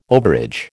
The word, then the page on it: overage: American English